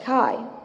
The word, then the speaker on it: chi: An X is actually a Greek letter pronounced chi, which is short for the meaning Christ.